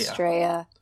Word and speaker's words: Estrella: Estrella. – With the double L in Spanish. – Yes. – Pronounced as a Y.